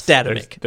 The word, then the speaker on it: Statamic: Statamic.